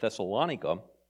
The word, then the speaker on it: Thessalonica: That's actually how they say it, Thessalonica.